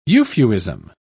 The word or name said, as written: euphuism